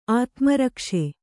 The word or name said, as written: ಆತ್ಮರಕ್ಷೆ